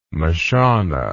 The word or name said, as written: mashona